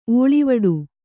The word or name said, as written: ಓಳಿವಡೆ